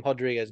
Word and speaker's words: Rodriguez: Like, Brazilian, if the R is at the beginning of the word, it's an H sound. And that is how you say his last name, Rodriguez.